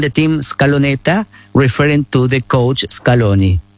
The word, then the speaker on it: Scaloneta: Scaloneta.